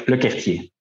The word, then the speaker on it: LeCourtier: Yeah, it's Thomas LeCourtier, but very exactly. Complicated French name.